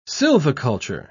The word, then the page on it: silviculture: British English